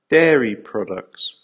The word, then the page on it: dairy products: American English